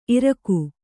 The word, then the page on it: ಇರಕು: iraku